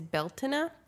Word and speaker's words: Beltane: And I just want to preface too that it's my understanding that the Irish and like the Gaelic pronunciation is Beltane, and there are quite different ways that people celebrate it.